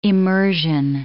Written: immersion

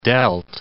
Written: delt

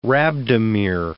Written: rhabdomere

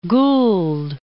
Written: Gould

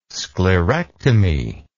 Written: sclerectomy

